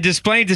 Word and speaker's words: Des Plaines: Yeah, and Des Plaines is too. It's one of those like French words that like you feel like the S should not be pronounced, but it is.